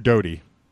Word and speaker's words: Doty: I'm not sure how to pronounce the last name. It's Doty. Doty or Dotty? – Richard Doty.